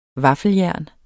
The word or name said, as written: VAFFELJERNET